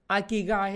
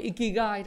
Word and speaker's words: Ikigai: Ikigai hay Ikigai đó tôi gọi là Ikigai tôi đọc tiếng Việt thuần Việt như vậy nhiều người đọc là Ikigai à,